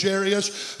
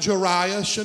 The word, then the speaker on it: Jairus: Some call him Jairus and others call him a few other things. But them are the two main ways that is pronounced, Jairus and Jairus. You can say it either way you want.